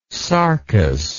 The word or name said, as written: sarcous